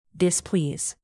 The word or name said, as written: displease